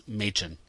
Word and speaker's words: Machen: Mackin is what I found. But The last one, it's Welsh, and I understand the Welsh pronunciation is Machen, like May and Chin.